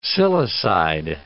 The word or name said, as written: silicide